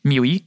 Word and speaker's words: MIUI: Hogy a MIUI, tehát ez hogy kell kiejteni, a MIUI, ami ugyebár a Xiaomi-nak a felülete. – A MIUI? – MIUI, igen, köszönöm szépen. Miau. Miau. Van a miau.